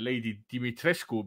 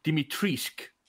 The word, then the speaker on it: Dimitrescu: Et see Lady Dimitrescu, mida hääldati hästi naljakalt. – Dimitrescu? – Ei, see neiu, kes seda ülekanne tegi, hääldas Dimitrisk. Ja ta väitis, et Capcom on niimoodi ette kirjutanud selle.